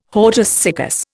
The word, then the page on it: hortus siccus: Indian